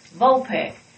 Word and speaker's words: Vulpix: Vulpix. Vols like Tennessee Vols. Peck like a bird does.